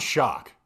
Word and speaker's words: Schoch: Yeah, no, mine's Shock.